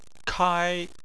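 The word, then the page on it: kai: tone 1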